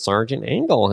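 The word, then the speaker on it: Sergeant Angel: And that's how they say his name. Mm-hmm. You know, like, oh, that's Sergeant Angle.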